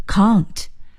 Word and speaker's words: can't: can't, C-A-N 加上撇加上 T 是不能的意思。 can, can't, 或者是念成 count。